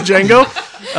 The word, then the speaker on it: Django: Good transition into Django Unchained 5 minutes.